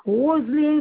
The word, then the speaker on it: Roseline: Je suis Madame Corutos, ça s'écrit avec K-O-R-U-T-O-S. – Oui. – Roselyne, ça s'écrit R-O-S-E-L-I-N-E.